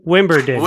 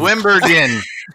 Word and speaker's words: Wimbledon: Wim- Wimberden, Wimbledon.